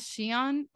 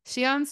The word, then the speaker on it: Siahn: But his name is SIAHN, or I believe that's how it's pronounced. It's S-I-A-H-N.